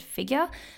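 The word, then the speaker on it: Figr: The first one that I want to talk about is an Australian brand called Figure. Hope I'm saying that right. It's F-I-G-R.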